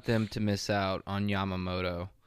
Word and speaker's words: Yamamoto: And his three free agent picks for the Cards is Nola, Monty, and Yamamoto.